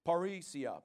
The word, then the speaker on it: parrhesia: All right, so this word confidence in verse 35, this word here, parrhesia, I think is how it's pronounced, P-A-R-R-H-E-S-I-A, parrhesia, I believe it is.